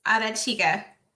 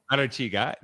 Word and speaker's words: Arechiga: And Valerie Nesmith-Arechiga, am I saying that right, Valerie? – Yes, Arechiga. – Arechiga,